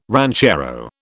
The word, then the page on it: ranchero: British English